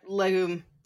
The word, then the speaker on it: Legum: So, despite all this, independent journalist, and I'm sorry, do you know how to pronounce his name? Independent journalist Judd Legum? – Yeah, I'll just say Legum. Okay. Is what I like, a bean.